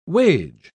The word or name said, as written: wage